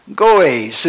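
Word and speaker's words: goes (γόης): And then the word for imposter is spelled, believe it or not, G-O-E-S with a long E, goes.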